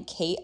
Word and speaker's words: Kait: Yeah, so perks of spelling Kate a little differently. So I spell it K-A-I-T instead of the usual way.